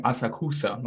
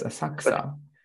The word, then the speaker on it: Asakusa: Or I was pronouncing Asakusa, my dumbass, right, when I was there. But it's Asakusa, which is a wonderful region, No research, because Japan wasn't going to open.